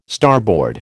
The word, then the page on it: starboard: American English